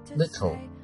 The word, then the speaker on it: little: 那么还有一个我们讲一下这个地方有一个单词的读音 little L-I-T-T-L-E，这个地方它有一个非常特殊的一个读法，因为它后面这个 L 这个 L 这个舌边 L 这个舌边呢它是跟着 T 这样一个爆破音的。但我们 T 我们知道这个音在发音的时候其实你的舌尖会顶住上行然后把它爆破出来 T，